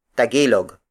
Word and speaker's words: tagilog: Its native Tagalog counterpart is Tagilog. Tagilog. Tagilog. Tagilog is spelled as T-A-G-I-L-O-G.